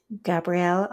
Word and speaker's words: Gabrielle: At Gabrielle Author,